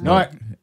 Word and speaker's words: Newark: No, I didn't, that's not the right way to say it. I'm actually making fun of people who actually say it. It's Newark. It's Newark. – Yeah, it's Newark. Now I'm from Newark, New Jersey, and, you know, obviously I grew up rough, but I'm from Haitian descent, and...